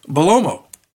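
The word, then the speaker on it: Bellomo: But in 92, Rockin' Rebel, Johnny Hotbody, Tony Stetson, and how do you pronounce Sal's last name? I continue to pronounce that wrong. – Sal who? – Is it Belemo? – Belomo. Salvatore Belomo.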